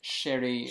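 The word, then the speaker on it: Cherie: I would pronounce it Sherry.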